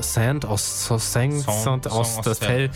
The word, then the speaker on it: Saint Austell: Saint Austell.